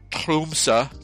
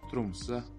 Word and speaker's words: Tromsø: And she's from Tromsø, Norway. And I am pronouncing that correctly. – Tromsø. Tromsø. Tromsø. – That's Wikipedia backing me up there.